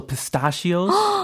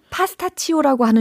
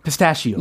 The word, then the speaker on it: pistachio: Also pistachios. – 파스타치오라고 하는 줄 알았는데 발음이 다르네요. – 아, 너무 다르다. – 우와. – Pistachio. – 파스 페, 피스타시오? – Pistachio. – Yeah. – Pistachio. 좀 soft한 발음.